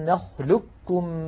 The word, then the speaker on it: نخلقكم: فكل الطرق عن حفص تقلب هذه القاف كافا طبعا ساكنة، وتدغم الكاف في الكاف فيصير النطق بكاف مشددة، هكذا ألم نخلق كم من ماء مهين.